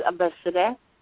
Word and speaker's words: Abosede: My name is Abeside. – Would you spell that, please? – A-B-O-S-E-D-E.